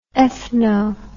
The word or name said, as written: ethno